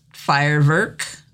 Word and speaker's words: Feuerwerk: And it's pronounced Feuerwerk in German.